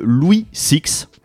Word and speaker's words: Louis Six: Voilà, c'est au cours de ces recherches que je suis tombé sur un monsieur qui s'appelle Louis Six. Je pense qu'on va le prononcer à l'anglaise parce que il nous vient...